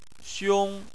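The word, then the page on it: xiong: xiong1; tone 1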